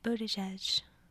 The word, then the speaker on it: Buttigieg: Pete Buttigieg. – That is how you say, I promise. He says it in his Twitter bio.